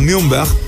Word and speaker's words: Nürnberg: En el Mix Deportivo, contándote además que Fabio Gruber, zaguero central, o Fabio Gruba, zaguero central de la selección peruana de fútbol, pasó ahora del Nuremberg, de la Bundesliga 2, o Nürnberg, que es como se pronuncia, al Mainz 05 de la primera división del fútbol alemán.